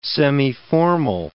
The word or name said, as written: semiformal